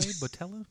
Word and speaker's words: Boutella: Starring Chris Pine, Zachary Quinto, Carl Urban, Zoe Saldana, Simon Pegg, John Chow, Anton Yelchin, Idris Elba, Sophia, how do I pronounce their last name? Botella? Does that sound pretty close? – Hold on. I'm looking at something else on the IMDb page. – B-O-U-T-E-L-L-A? Botella? Sure.